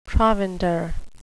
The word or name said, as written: provender